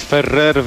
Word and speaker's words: Ferrer: Dawid Ferrer wygrał turniej na kortach ziemnych w szwedzkim Bastad. W finale pokonał Ukraińca Aleksandra Dołgo. Połowa 6-4 i 6-4. – Jak ładnie zaakcentowałeś Ferrer.